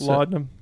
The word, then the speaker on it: laudanum: But anyways, like I was saying, there was nothing that would have shown, what is it, laudanum? How do you pronounce laudanum.